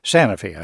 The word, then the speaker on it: Sanofi: The producer of the top-selling insulin in this country is Sanofi. I hope I'm pronouncing that correctly. – Yes.